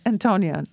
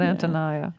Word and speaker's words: Antonya: And in fact, I wasn't even sure how you pronounced her name, whether it was Antonya, like my Antonya, or Antonya. How did you say it? – Antonya. – Antonya, not Antonia.